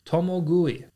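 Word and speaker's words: tomogui (共食い): Today's Lessons is going to be the Japanese word for cannibal, and that is tomogui. Tomogui is spelt T-O-M-O-G-U-I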